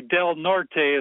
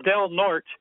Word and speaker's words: Del Norte: Up in California, there's a county, Del Norte. That's how you say it in Spanish. But the locals, they pronounce it Del Norte. And Terry Bradshaw, he gives a little promotional thing about promoting Del Norte County, and that's how he pronounces it. And that's how the mayor of the city, Crescent City, pronounces it, Del Norte.